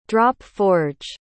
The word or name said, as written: drop-forge